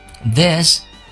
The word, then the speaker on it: this: This isn't as bad as I thought. 这里我们先讲一下发音。第一个单词 this 这个发音呢，应该发成 this this。把舌头放在牙齿的中间，发成 this this isn't as bad as I thought。